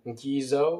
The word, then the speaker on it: Gezo: And the reason why, and that's the reason why in the 1840s, the king, his name, well, it's spelled G-E-Z-O, so I'll pronounce it Gizo or Gezo, King Gezo of Dahomey, in the 1840s, said the following.